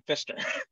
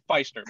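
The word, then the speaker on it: Pfister: It's like when Wally Fister, I never like to call him Fister. Very poor pronunciation, but I've heard Fister more than Feister. But I'm gonna say Feister so that nobody starts thinking about fisting. So I'm glad that I dodged that bullet.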